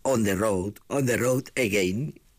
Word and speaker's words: On the Road Again: In the Road Again, que ahora Norberto me corregirá en la pronunciación. No, bien, on the road, que he dicho pues, on the road again.